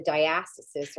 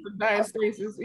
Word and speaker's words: diastasis: And then I have the diastasis, or whatever the hell it's called. Is that how you say it? – That's it. Oh, diastasis, either one.